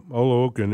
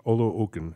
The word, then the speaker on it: Oluokun: And, you know, so Oluokun is the way you pronounce it. Oluokun.